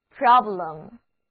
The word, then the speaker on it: problem: Problem。字母 E 读作 A。